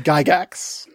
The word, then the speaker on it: Gygax: I've even met the guy and I still don't know how to say his name. – Hard G. – Gygax? – Gary. – Gygax.